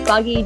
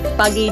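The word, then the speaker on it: Buggie: So my Instagram's, well, it's pronounced Buggy James, B-U-G-G-I-E. J-A-M-E-S, which is my last name. So Buggy James, but then you'll find me on there with all my content for you to view over.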